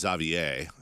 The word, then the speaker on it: Xavier: And the proper pronunciation, by the way, I believe is Xavier has beaten Arizona 73-71.